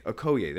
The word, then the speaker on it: Okoye: Okoye.